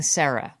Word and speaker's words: Sera: So, as we mentioned, Sarah's name is spelled S-E-R-A. – Or Arse, A-R-S-E. – Yes, and I assume it's pronounced Sarah, right? Not like Sierra.